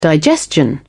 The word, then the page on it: digestion: British English